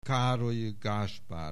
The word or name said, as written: Károlyi Gáspár